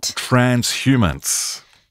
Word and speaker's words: transhumance: Transhumance. – Transhumance? A-N-T-S? – No, transhumance. H-U-M-A-N-C-E. – Oh, I'm trying to get this pronunciation guide. – Okay. – Yeah, transhumans.